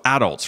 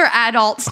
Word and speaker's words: adult: And you put the emphasis D. on the adult.